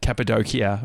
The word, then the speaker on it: Cappadocia: Oh, yeah, the Cappadocia, or however you say it.